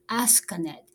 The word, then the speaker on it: Asukanet: Actually, because this is a Japanese company, so there is a U after S, Asukanet. But their new product is spelled Asuka3D, which is spelled A-S-K-A-3-D.